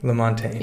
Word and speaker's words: LaMontagne: Lamontagne.